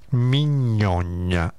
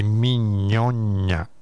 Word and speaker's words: Mignogna: Mignogna, come mignotta, però il finale è un GNA. Quindi Mignogna, che segue un po' gli stoici, mi sembra di aver capito, non lo conosco bene.